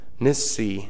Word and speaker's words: Nissi: It's N-I-S-S-E-E. If you want to write the pronunciation, N-I-S-S-E-E with the accent on the last syllable, Nissi.